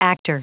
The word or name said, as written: actor